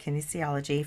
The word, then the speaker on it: kinesiology: Kinesiology. Am I saying that right? – Applied kinesiology. Yes. Yes.